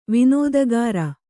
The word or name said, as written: ವಿನೋದಗಾರ